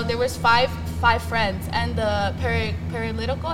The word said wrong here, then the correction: paralytic: am I pronouncing it right? – Paralytic.